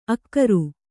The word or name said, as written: ಆಗ್ರ